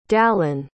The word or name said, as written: dallin